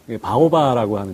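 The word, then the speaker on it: 바오바라고 (保八): 그래서 이걸 중국말로 바오바라고 하는데요. 우리 한자로 표시하면 이제 보호할 보자에다가 여덟 팔자 써가지고 이것이 중국 발음으로 바오바라고 합니다.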